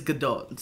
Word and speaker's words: Gadot: And Jal Jadot. – Yeah. Is that anti-Semitic, pronouncing her name that way? – Was it? Well, her name's Gal, but her last name is Gadot, so...